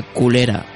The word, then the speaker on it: Colera: El primero nos fuimos a una playita cercana por Bo llamada Colera, que en catalán se pronuncia culera, jajaja, ja, ja.